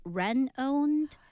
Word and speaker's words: renowned: But you're like, because it's spelled R-E-N-O-W-N-E-D. So it's like renowned. – Renowned, that's how, Because it seems like that's just a natural cutoff, but it's renowned.